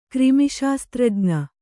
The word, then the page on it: ಕ್ರಿಮಿಶಾಸ್ತ್ರಜ್ಞ: krimi śastraj'na